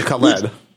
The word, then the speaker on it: Khaled: What about Rihanna's duet with DJ Khalid? – I loved it. – And am I getting his name right? – I think it's Khaled or Khalidia, one or the other. – Khaled. – Yeah, Khaled. I'm first of all, anything Riri, I'm down for.